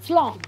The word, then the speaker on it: Slán: Wie sagt man nochmal Tschüss? – Slán. – Slán. – Yes. That was it. – Slán.